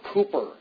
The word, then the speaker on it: Cowper: It was while he was ministering in Olney that Newton became closely associated with William Cowper, or Cooper, as some pronounce his name.